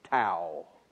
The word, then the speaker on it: tau: I remember coming down to the end and pronouncing the sin, shin, tau. T-A-U is the name of the letter. It's a tau.